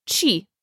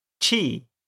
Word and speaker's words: چه: What is چه? In conversation, you'll usually hear it pronounced چی. – چی.